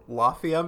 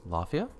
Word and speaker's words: Lafia: Well, I did find out the director of the movie, John Lafay? No, I'm going to try it again. It's spelled like mafia, but with an L, so Lafia, maybe? – Lafia, yeah.